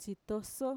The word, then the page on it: txitoso: Mixtec